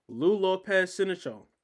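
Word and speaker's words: Lou López Sénéchal: Lou Lopez Sinichel. I said that right? – Yes, you did.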